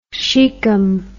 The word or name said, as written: sychem